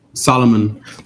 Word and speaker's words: Salomon: Salomon XT6 Rhine Kangaroo colorway.